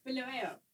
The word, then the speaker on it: Bulawayo: Bulawayo.